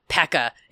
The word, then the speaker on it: Paka: It's called Pekka. It's spelled P-A-K-A.